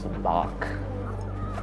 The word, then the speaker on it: Sabacc: So this is Sabacc? – Sabacc. – Sabacc. Got it.